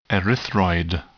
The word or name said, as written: erythroid